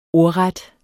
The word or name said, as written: ordret